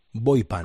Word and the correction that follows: Boipam: Como es Boipam, si sí, el buey es de boya y el PAM es de Passive Acoustic Monitoring,